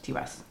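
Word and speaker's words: Ti West: And was this Ty West's second film? – T West. – Excuse me. – I know for years we were saying it wrong.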